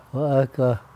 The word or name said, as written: we-ekah